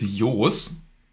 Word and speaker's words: Joes: J-O-E-S. – Mit westfälisches Dehnungs-E. Genau, westfälisches Dehnungs-E. Ey, Sebastian kennt sich aus. Die Niederländer so würden dich Jus aussprechen. – Wie? Jus. Jus? – Ja, O-E ist dann U.